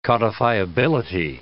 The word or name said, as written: codifiability